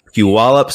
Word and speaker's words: Puyallup: Puyallup Sumner Chamber of Commerce.